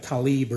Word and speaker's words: Tlaib: That's why that Islamic trader Tlaib, or whatever her name is, it's spelled T-L-A-I-B, Tlaib, but they say it differently. They say like Talib or something. She ought to just correct the spelling. That way we can pronounce it T-I-L-A-B, you know.